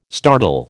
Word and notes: startle: American English

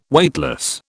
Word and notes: Weightless: British English

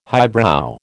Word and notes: highbrow: American English

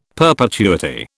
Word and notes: perpetuity: British English

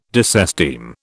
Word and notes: disesteem: British English

